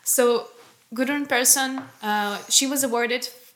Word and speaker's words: Gudrun Persson: Can I see? I can maybe... Gudrun Persson. Gudrun Persson. – All right. Maybe it is how it sounds. – It's Gudrun Persson. Something like that.